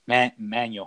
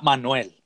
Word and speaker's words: Manual: So it's spelled M-A-N-U-A-L. Manual High School. Not Manuel, but Manual.